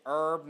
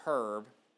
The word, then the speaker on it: herb: But, you know, it's herb, not herb. I get it, because we're not in Britain.